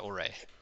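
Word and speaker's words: Traoré: How do you pronounce that? Trowel? – Which one are you saying? – Traore? Traore? – Traore? Oh, Traore? – Traore. Yeah. – Traore? – Yes.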